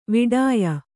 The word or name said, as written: ವಿಡಾಯ